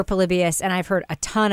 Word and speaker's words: Polybius: And also, is it the Polybius Conspiracy? That's how it's pronounced? Polybius, not Polybius, as my son says. It's Polybius. – I think it's Polybius. That wasn't what I was expecting. Yeah. That's how they pronounced it.